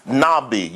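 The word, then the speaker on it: nabi (נביא): The first word is nabi. You spell it N-A-B-I-Y, nabi.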